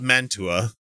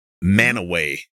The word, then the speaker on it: Mantua: This one's pronounced weird too. Ma- Mantua. It, uh, it's spelled, that's the Italian. – It's spelled Mantua, you know, but yeah. it's Manaway.